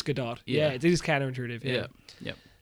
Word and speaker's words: Gadot: Are you sure you're saying that right? – Yes, I am. I googled it. Gadot. – I want to have it have the French spell, the French pronunciation, but I guess it doesn't.